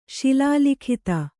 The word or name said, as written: ಶಿಲಾಲಿಖಿತ